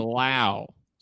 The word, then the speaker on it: Lowe: It looks like Lowe, but they're both L-O-W-E, but apparently it's Brandon Lau.